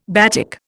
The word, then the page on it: batik: Indian